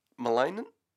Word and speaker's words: malignant: Is that how you say the word? I haven't said it out loud yet. How do you say it? – It's malignant. – Malignant! I thought the G was silent, maybe. – Like lasagna? – Like benign. – Okay.